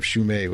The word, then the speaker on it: Shumay: just write it down how it's spelled. – Jeff, Shume. Just write Shu, and then the month of May.